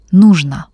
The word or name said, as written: nyzhno